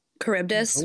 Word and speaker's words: Charybdis: And that's how they pronounce it. – Charybdis. – Yeah.